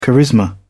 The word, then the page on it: charisma: British English